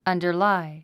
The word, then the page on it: underlie: ʌ̀ndə r lái; アンダァライ